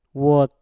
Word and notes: wot: mpur

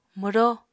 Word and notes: mwró: Apatani

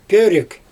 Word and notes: pörIk: Xyzyl

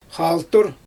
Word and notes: xaaltur: Xyzyl

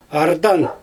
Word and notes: ardan: Xyzyl

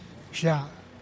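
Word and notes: xia: Teotitlán del Valle Zapotec

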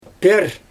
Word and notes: pIr: Xyzyl

